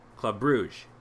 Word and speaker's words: Club Brugge: Club Brugge, which is, according to our Belgium expert Rob Walker, the correct pronunciation.